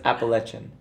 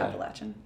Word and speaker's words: Appalachian: But that right now I'm finding out the mountain range that runs right near my home, I've been mispronouncing. – That's right. – Okay. So it's Appalachian. – Appalachia. – Appalachia. – Or Appalachian. Yeah.